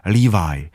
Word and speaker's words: Levi: A pak jsou džíny a to jsou, jako říkáme, Levisky, že to je s jednoduchým V, ale ty se mají vyslovovat jako Levi, tedy s tím S na konci by to mělo být Levisky vlastně, džíny.